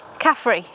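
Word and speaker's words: Khafre: It's K-H-A-F-R-E. – Caffrey. – Yes. – Yeah. – Caffrey. It's the Irish beer from the 1990s.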